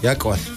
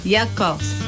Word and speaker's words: Yakos: In Dene Willy, how do you say clouds? – Yakos. – Yakos. – Yakos. – Yakos. – Yeah, clouds.